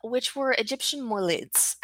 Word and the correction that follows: mulid: And I'm pretty sure I'm not pronouncing that properly. So would you say that for me? – A mulid. – Mulid. Thank you. – Mulid, yeah.